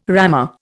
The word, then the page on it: rammer: Indian